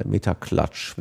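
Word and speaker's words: MetaClutch: C-L-U-T-C-H, ich denke mal, Meterklatsch wird's ausgesprochen.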